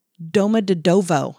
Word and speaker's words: Domodedovo: And she was born in, okay, now careful with this one, Domodedovo, I believe is how you say that, a working class town located southeast of Moscow.